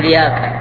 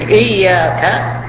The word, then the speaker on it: إياك: لكن لا يبقى على حاله فيقول إياك، ليس لها معنى. لكن إياك هذا ضمير منفصل مفعول مقدم لنعبد، إياك نعبد، فيجب أن تنطق آيات القرآن عموما على كما أنزلت، وبالأخص سورة الفاتحة التي هي ركن من أركان الصلاة.